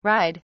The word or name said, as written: ride